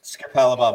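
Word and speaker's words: Capalaba: It's Capalabar, mate.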